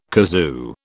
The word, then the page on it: kazoo: British English